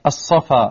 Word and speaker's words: Al-Safa: Al-Safa, you don't pronounce the lam.